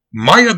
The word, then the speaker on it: Maiya: Next up, we have Maya the Dawn. which is spelled, M-A-I-Y-A, which, I mean, that's a good name. So yeah, Maya the Dawn, she hails from Brooklyn.